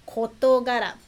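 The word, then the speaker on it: 事柄: E preste atenção, kotogara, pronúncia mudou. Então, a maioria das palavras é assim, quando, né, usa dois kanjis seguidos, é, pronúncia seria de, mas pode ser diferente, né?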